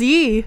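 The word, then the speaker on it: Ye: Is it yay or yee? – Yay. – Yeah. See? – No. – Kanye. Yay. – But he goes by yee. – It's yee? Pronounced yee. – Am I wrong? – Yeah. – There's no way. – It's pronounced yee.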